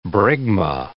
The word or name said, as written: bregma